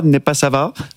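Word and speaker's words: Népszava: C'est un article du média hongrois, Népassava. Je le prononce bien prononcé.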